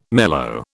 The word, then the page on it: mellow: British English